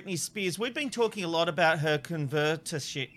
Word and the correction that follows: conservatorship: I can't even say it. How do you say it? – Conservatorship. – Conservatorship. Thank you very much.